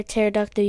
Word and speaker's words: pterodactyl: Why can't you hear pterodactyl use the bathroom? – Why is it? – Because the P is silent.